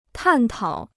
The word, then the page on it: 探讨: tàn tǎo